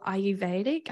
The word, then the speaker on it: Ayurvedic: Ayurvedic. I've probably butchered that. – Yes. No, that's right.